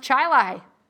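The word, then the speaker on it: Chili: The station itself was in Chile, which I had to stop myself because there is a town in New York that is spelled Chile like the soup. And it's pronounced Chilai, which is really dumb.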